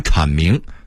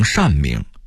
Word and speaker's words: 阐明: 当然我的那些师傅说话时的俄化字就更多了，他们一直把剥削念成剥削，把阐明说成善明啊，因为门字里边的单字也念善，把压迫念成压迫，因为有迫击炮这个名词。